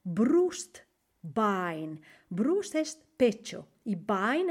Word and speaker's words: Bein: Das Bein. Bein. Se escribe, ojo, B-E-I-N. Das Bein.